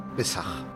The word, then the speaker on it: Pesaj: Pesaj.